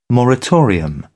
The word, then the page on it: moratorium: British English